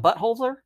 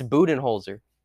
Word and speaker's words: Budenholzer: Because how do you say his name? It's not Buttholzer? – It's Budenholzer.